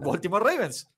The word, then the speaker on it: Baltimore Ravens: Los antiguos y ahora nuevos Cleveland Browns contra los que alguna vez fueron los Cleveland Browns, que ahora son los Baltimore Ravens.